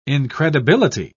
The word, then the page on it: incredibility: British English